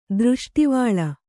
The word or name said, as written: ದೃಷ್ಟಿವಾಳ